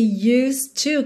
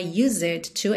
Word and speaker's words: used to: Olha esse used to que a gente viu recentemente aqui em outras aulas, então nada de pronunciar essa letra E, não é used to, é used to, ok?